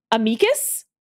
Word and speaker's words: amicus: There are two kinds of legal kibitzers: those who pronounce A-M-I-C-U-S amicus, and those who pronounce it amicus.